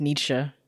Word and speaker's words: Nietzsche: Wait, actually, I was going to say, can you help me pronounce this? – I can. – Okay. Nietzsche. – Can you? – Do you Nietzsche? Yeah, Nietzsche. I'm going to go with Nietzsche. I think it's Nietzsche. – It's pronounced Nietzsche.